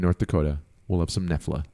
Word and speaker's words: Knoephla: Who lives in North Dakota, and this is pronounced Nefla, and it is a thick German soup with dumplings. – It's spelled K-N-O-E-P-H-L-A.